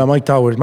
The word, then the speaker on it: Mike Towers: Yeah, Mike Towers. Mike Towers is one of the... – That track is crazy. – Oh, Billetes de Cien, I love it. You know, yeah. And Mike Towers, it's basically one of the best MCs, period.